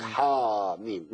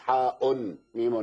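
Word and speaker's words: حم: ألف لام ميم صاد دي في الأعراف، وبعدين حاميم في غافر وفصلت والزخرف والدخان والجاسية والأحقاف، يقول لك حا ميم مش حا أن ميم، لا تتنطق حا ميم.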